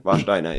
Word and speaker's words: Warsteiner: Did I say that right? – Yeah, and Warsteiner, yeah.